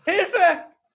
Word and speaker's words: Hilfe: Hilfe.